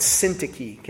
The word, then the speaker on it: Syntyche: And Syntyche.